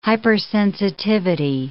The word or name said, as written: hypersensitivity